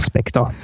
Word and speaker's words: Spectre: Und ich muss sagen, so wird es ausgesprochen. Hört ihr das? – Hört was? – Könnt ihr das hören? Ah. – Ja, nein, danke. – Doch, Spectre.